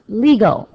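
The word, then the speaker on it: lego (λέγω): One is pronounced laleo, L-A-L-E-O, and the other is pronounced lego, L-E-G-O. In our text here, the Greek verb for speak is laleo, L-A-L-E-O.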